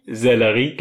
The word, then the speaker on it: Sellerie: Also jeder, der jetzt nicht aus dem Begriff Sellerei was Hä, findet... Sellerie? – Sellerie gibt's als Gemüse.